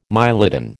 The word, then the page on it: mylodon: American English